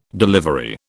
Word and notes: delivery: British English

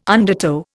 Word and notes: undertow: Indian

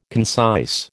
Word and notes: concise: American English

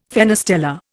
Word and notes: fenestella: Indian

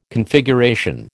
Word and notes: Configuration: American English

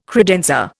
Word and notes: credenza: Indian

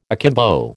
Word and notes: akimbo: American English

